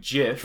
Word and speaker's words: GIF: He goes, guys, everyone knows it's a G sound, as in like GIF. – Graphics. – Because the G in GIF stands for graphics. – Graphics. – You know, you use the hard G in order to...